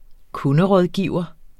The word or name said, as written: kunderådgiver